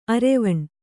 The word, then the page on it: ಅರೆವೆಣ್ಣು: areveṇṇu